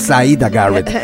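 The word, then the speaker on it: Siedah Garrett: Así es, y bueno, ya extrañaba la pronunciación perfecta que tienes, porque sí es Syreah Garrett, tienes toda la razón.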